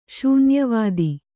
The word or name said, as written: ಶೂನ್ಯವಾದಿ